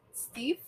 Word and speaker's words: Steiff: So the one-of-a-kind doll was made by the Steiff Company. – How do you spell it? – S-T-E-I-F-F.